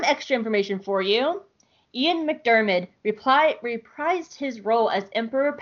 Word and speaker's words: McDiarmid: McDiarmid, yeah.